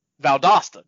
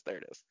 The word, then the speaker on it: Valdosta: Valdosta. – There it is. Valdosta.